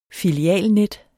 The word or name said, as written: filialnet